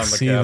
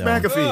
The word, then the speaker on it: McAfee: Why would it be Mac if there's no A between the M and the C? It's Mick. – Well, that's how he says it. And in his like McAfee. Interview, he's like, I'm John McAfee.